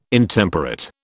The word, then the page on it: Intemperate: British English